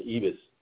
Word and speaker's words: Ribas: She bloodied up, I know it's spelled R-I-B-A-S, but they kept pronouncing it Ibis.